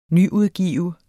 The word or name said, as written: nyudgive